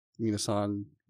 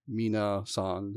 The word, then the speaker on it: Miiinasan: Miiinasan, Miiinasan. That's how you say my name in like Okinawan and Japanese.